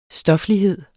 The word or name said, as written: stoflighed